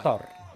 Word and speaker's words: Qatar: Qatar. – That's it. That's the proper pronunciation. One more time. One more time. – Qatar. – Qatar. – Oh, it's tough, though. That rolling R is tough.